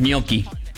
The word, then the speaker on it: gnocchi: Que la pronunciación de gnocchi en italiano es gnocchi. – No, pero pone ñ, ya que tenemos ñ.